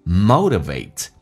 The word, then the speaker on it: motivate: Have to motivate，英式发音叫 motivate，美式发音叫 motivate。首先那个 t 要被浊化，那 e 去向一发 a 这个发音叫 motor，咬下唇 vate， a 这个发音要饱满，前倾后冲 motivate yourself。